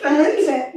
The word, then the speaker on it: Leavitt: Leavitt, yeah. – Are you sure it's Leavitt? How do you spell it? – It's L-E-A-V-I-T-T.